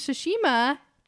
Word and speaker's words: Tsushima: So the Ghost of Tsushima, am I saying that right? – Yes, you are.